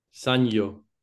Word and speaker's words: Sanyo: Is it Sanyo or Sanjo? – Sanyo. – Sanyo.